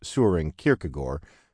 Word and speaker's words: Søren Kierkegaard: pronounced Søren Kierkegaard, and wonder what's up.